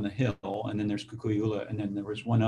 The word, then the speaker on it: Kukui'ula: Kukui'ula.